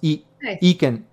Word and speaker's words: EKYN: Pronounced Eken. Eken? Eken? – Yeah.